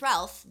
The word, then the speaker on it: Ralph: Like I've heard like Ralph before the debate, is it Ralph or Rafe? And I was like, well, if William Golding says it's Ralph, then it's Ralph.